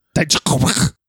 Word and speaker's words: Dijakovic: to his many monikers of the Viking Raiders, Oni Lorcan / Biff Busick, you know, Sasha Banks / Mercedes KV, Dominic Dijakovic. How do you say it? – Dijakovic.